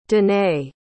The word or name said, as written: denae